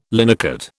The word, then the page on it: linocut: British English